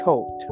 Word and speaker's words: Thoth: It's, first of all, it's spelled T-H-O-T-H, Thoth or Tote.